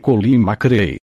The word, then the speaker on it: McRae: A pronúncia correta é Colin McRae.